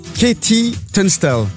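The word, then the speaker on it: KT Tunstall: C'est signé Katie Tunstall.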